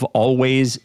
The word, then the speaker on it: Alvvays: Always is spelled A-L-V-V-A-Y-S.